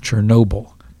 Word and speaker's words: Chernobyl: it's pronounced Chernobyl.